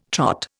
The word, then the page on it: Trot: Indian